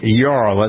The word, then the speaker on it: Yarl: The shooting of Ralph Yarl, that's how we pronounce his name, right? – Yes.